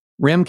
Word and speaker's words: Rehm: And now, and I'm going to mispronounce your name, Rehm, R-E-H-M. Would you concur that that's pronounced Rehm? – Yep.